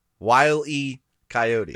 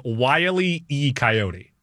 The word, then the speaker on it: Wile E. Coyote: Before we go any further, pronounce the character's name right. – Wile E. Coyote. – Thank you. And don't get it wrong again. – That's what I said. – You said Wile E. Coyote.